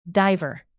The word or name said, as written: diver